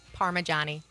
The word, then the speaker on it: Parmigiani: Parmigiani. – Parmigiani. – Yeah, so Parma, like the region in Italy, yeah.